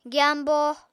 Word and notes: gyambo: apatani

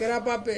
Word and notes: kerapape: Ansus

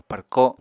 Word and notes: párko: apatani

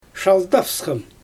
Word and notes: ʃaldavsxam: Xyzyl

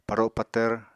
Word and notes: paro patvr: Apatani

